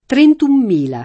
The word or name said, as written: trentuno